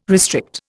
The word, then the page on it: restrict: Indian